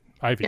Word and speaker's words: Ivey: Ivey. – Yep. It's spelled I-V-E-Y, that's why I asked.